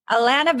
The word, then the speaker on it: Allana: Allana Banana.